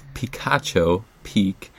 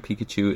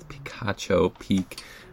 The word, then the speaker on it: Picacho Peak: I'm currently at, it's pronounced Picacho Peak. It's P-I-C, oh dear, I have the spelling all wrong now. Anyway, we were saying Pikachu, it's wrong, it's Picacho Peak.